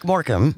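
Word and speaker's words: Morecambe: It's Eric Morcombe.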